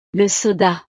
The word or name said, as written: le soda